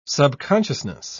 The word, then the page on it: subconsciousness: British English